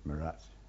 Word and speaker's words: Meerut: Meerut. Some people say Meerut, but I've always said Meerut. It's the way we pronounce it.